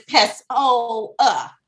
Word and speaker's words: Pessoa: But it rhymes with hoe, like think hoa. – Yeah, like, I was like, I'm not a hoe, but my name is Pessoa.